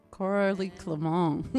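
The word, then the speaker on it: Coralie Clément: So is it Coralie Clement? Or is it Coralie Clement? – It's Coralie Clement.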